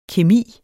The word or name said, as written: kemi